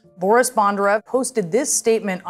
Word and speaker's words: Bondarev: And you basically have an interview with this guy, Boris Bondarev. Correct me if my pronunciation is wrong. – Yep, that was it. That was perfect.